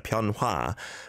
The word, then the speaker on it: 변화: And 변화, right? It's the or vowel sound on the heard hua. But it doesn't need to be overly pronounced, but still 변화 무쌍하네.